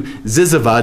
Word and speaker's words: zyzzyva: Now, when I've talked about this previously, I've said it was all the way from aardvark to ziziva. Ziziva is a type of insect, and it's spelled Z-Y-Z-Z-Y-V-A.